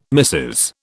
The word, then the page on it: missis: British English